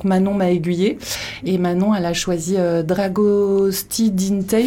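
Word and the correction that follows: Dragostea din tei: je sais pas comment ça se prononce, je sais pas si c'est comme ça Manon. Dragosta Dintei. – Ah merci.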